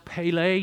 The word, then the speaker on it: Pele: Now, the two words translated into English, Wonderful Counselor, are actually Hebrew words that you would just pronounce Pele Ya'ah. And that first word, Pele, yes, that sounds a lot like the former great Brazilian soccer player.